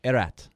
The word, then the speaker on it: Erat: And the Czech representative, who was the media person there, went to Pete and Terry and said, you guys are saying his name wrong. It's Erat. Erat.